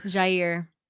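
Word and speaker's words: Jaire: I think you're going to see I'm always butcher this. – Jair. – Thank you.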